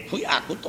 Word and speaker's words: 旧訳: そういう言葉を旧訳と書いて苦訳と読むんです。